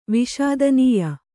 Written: ವಿಷಾದನೀಯ

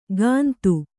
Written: ಕಂದು

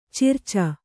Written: ಚಿರಚ

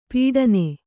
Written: ಪೀಡನೆ

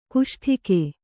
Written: ಕಸೊಡಿಗೆ

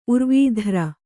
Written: ಊರ್ವೀಧರ

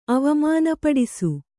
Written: ಅವಮಾನಪಡಿಸು

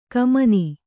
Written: ಘಮ್ಮನ

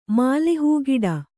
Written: ಮಲೆಹಾಕಟು

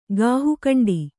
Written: ಗಾಹುಕಂಡಿ

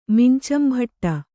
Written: ಮಿಂಚಂಭಟ್ಟ

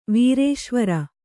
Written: ವೀರೇಶ್ವರ

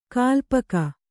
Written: ಕಾಲ್ಪಕ

